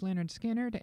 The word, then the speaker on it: Lynyrd Skynyrd: So I think I'm going to go all the way back to their very first album, pronounced Lynyrd Skynyrd, and do a track off that, I Ain't the One.